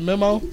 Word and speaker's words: MEMO: So tell us more about, you pronounce it Memo? – Yes.